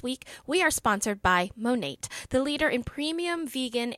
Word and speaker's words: Monat: And Monate is pronounced Monate, which we know because we've YouTubed it several times, but it is spelled M-O-N-A-T.